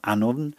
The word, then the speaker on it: Annwn: Now, Annwn there, the way that Annwn is spelt, That's the colloquial, or what we might say, the folk spelling of Anwen. In classical Welsh prose, of course, it's Anwen with the F.